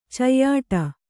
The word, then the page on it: ಚಯ್ಯಾಟ: cayyāṭa